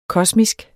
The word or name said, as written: kosmisk